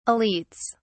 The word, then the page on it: elites: British English